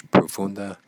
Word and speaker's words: profunda: Profunda humillación. Humillación. So profunda humillación. Humillación. Humillación. – Humillación. Humillación. – And you kind of have to roll your R's sometimes. It's like pero, pero, profunda, profunda.